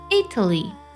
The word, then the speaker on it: Italy: 最后一类错误集中体现在重音上，比如说国家名意大利，那么它的重音呢是在首字母 I 上，它读作 Italy。